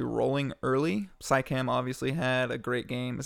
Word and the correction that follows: Siakam: Is that how you say his name? Psycam? – Uh, I don't know. Siakam, Psycam. oh, that's right. Siakam. That's how you say it. Sorry, everyone. Totally said it wrong.